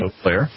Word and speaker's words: Eau Claire: And she's in I'm going to say it right here, Eau Claire. Right? – Eau Claire, that's right. – Eau Claire, I said it right.